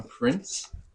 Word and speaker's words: Prince: I sing, and it's spelled S-A-M. – I'm Prince. It's P-R-I-N-C-E. I play bass. – Sky, that's S-K-Y-E, and I play guitar and I sing.